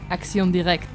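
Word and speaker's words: Action directe: A ver, pronunciación francesa. – Acción Directe. – Ahí estamos.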